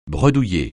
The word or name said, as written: bredouiller